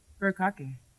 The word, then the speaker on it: furikake: Furikake, omikami, boken. – Furikake. – That's it. Oh, so that's how you say it.